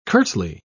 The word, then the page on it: curtly: British English